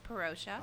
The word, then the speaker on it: Parousia: It's spelled P A R O U S I A. Is it the Perosia or the Perusia? According to the YouTube people, it's Perosia. – Okay, I've been pronouncing it Perosia.